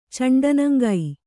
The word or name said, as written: ಚಂಡನಂಗೈ